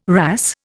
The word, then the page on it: wrasse: Indian